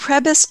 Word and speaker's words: Prebys: These include University of California, San Diego, School of Medicine, Prebys? – It's pronounced Prebys Cardiovascular Institute, which is a wonderful part of the Scripps Health Network down in San Diego.